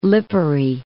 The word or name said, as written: Lipari